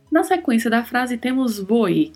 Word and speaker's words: voy: Temos que nos atentar aí para a letra V, que tem um leve som de B, e a letra O, que é mais fechada. Voi, voi.